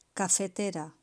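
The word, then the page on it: cafetera: Spain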